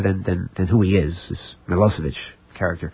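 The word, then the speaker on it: Milošević: It's spelled Milosevic, but it's pronounced Milosevic.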